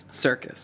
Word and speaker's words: Serkis: S-E-R-K-I-S. – Serkis.